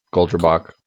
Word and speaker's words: Goldrabach: Is then they find that magical spell book, the Goldra book. Is that how you pronounce that? – Yeah. Goldrabach. – Goldrabach.